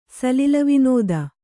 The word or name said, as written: ಸಲಿಲವಿನೋದ